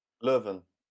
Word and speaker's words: Leuven: How'd you pronounce it again? Leuven? – Leuven.